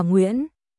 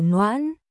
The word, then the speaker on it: Nguyễn: Nguyên Tình, họ của tôi phát âm là Nguyễn, không phải Noãn.